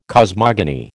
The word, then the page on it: cosmogony: American English